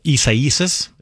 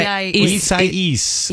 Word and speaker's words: Isaias: I think you're adding an extra Isaias. – There you go. – Isaias.